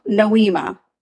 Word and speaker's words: Nohema: And they pronounce it, they're better at it than I am. – Is it Nohema? – Nohema, but they say it fast and like it flows beautifully.